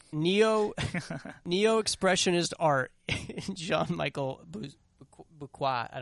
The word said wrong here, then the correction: Basquiat: Basquiat? – Yeah, is that how you say it?